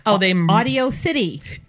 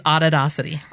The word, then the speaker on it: Auditocity: Do you kind of laugh when people try to pronounce it? Like, Audio City. – Audio City. Yeah. Audacity. That's a good one. – Say it again. – It's Auditocity.